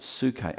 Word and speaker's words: psuche: It's the word psuche. Silent P at the front of it, psuche.